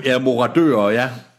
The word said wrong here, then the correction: marodører: Eller marodører, undskyld, jeg kan aldrig udtale det. Marodører. Det er, marodører.